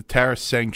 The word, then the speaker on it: Tarasenko: Oh, no. Say it right. It's the Tarasenko show.